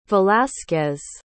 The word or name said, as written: velazquez